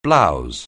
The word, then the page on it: blouse: anglais